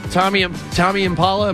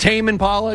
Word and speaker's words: Tame Impala: Is this Tommy Impala? Am I saying that right? Is it Tame Impala? It's spelled Tame.